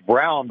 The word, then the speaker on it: Braun: I guess it's pronounced Brown, but it's spelled Braun.